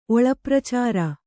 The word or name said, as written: ಒಳಪ್ರಚಾರ